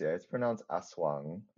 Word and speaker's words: Aswang: So I'm like, it's probably not Wang, as in, like, European way of reading mm-hmm. that. So, yeah. – It's pronounced Aswang.